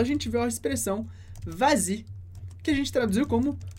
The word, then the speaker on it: vas-y: Então, no caso do y, quando a gente fala vas-y especificamente, a gente acrescenta um s para que a pronúncia fique mais fácil.